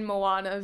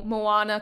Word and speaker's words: Moana: It'll be like, but the way to pronounce it is Tamsyn, Moana, Veale. So like Tamsyn as normal, Moana, kind of like the movie, and then Veale, like the meat.